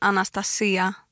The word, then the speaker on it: Anastasia: Om jag ska på grekiska så säger man Anastasia med betoningen på i-et då.